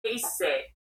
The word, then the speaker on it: Issey: Pronunciation would be Issey.